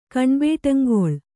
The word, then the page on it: ಕಣ್ಬೇಟಂಗೊಳ್: Kannada